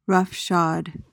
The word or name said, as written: roughshod